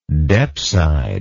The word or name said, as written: depside